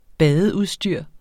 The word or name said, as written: badeudstyr